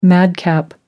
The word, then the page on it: madcap: American English